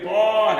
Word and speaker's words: طه: والألفين في طه تنطق بالفتح والإمالة، وجهان منزلان عندي، إما تقرأ بالفتح بتقول طه في ألف، وممكن تقرأ بالإمالة فهو حذف الألف، واخد بالك؟